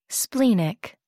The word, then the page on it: splenic: American English